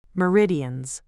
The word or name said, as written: meridians